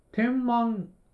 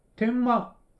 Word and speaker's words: 天満: で、 3 日目の日曜日が大阪観光から始まったんですけど、天満、天に満ちる、満ちると書いて天満と書くところに行ったんですけど、これ、天満じゃなくて天満と読むそうです。これ駅降りた時にひらがな表記の駅看板を見て気づいたんですけど、天満というところに行きました。で、大阪天満宮っていうのが有名なところで、その参道みたいな感じで、日本一長いのかな？